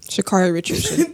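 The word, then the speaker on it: Sha'Carri Richardson: Shakari Richardson.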